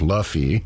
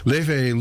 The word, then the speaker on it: Laufey: She goes simply by her first name, which is spelled L-A-U-F-E-Y. Looks like Luffy, but is pronounced Leve. Leve is a half-Icelandic, half-Chinese artist.